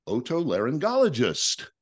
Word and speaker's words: otolaryngologist: Her father, Dr. Flexen, is an otolaryngologist.